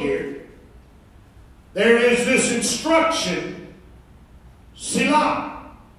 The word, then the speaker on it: Selah: We pronounce it Selah.